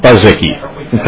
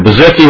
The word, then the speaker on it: Bezicki: If Mr. Bezicki? – Bezicki. – Bezicki. Come forward. Sorry, sir. Mr. Bezicki, welcome to the Committee on Ways and Means. Am I pronouncing your name right? – Mr. Chair, yes, you are.